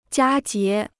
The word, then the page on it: 佳节: jiā jié